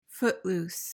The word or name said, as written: footloose